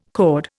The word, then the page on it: cord: Indian